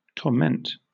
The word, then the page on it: torment: British English